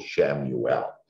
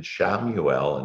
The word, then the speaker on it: Chamuel: So the first one is Archangel Shamuel. And that's for Sunday. And there's a color associated with every archangel. And Shamuel, and it's spelled Chamuel.